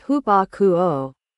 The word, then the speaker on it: hupakoo: common Bible Greek word, hupakoo, pronounced hupakoo, means to stand under hearing, and is, slightly, mistranslated, obey in English Bibles.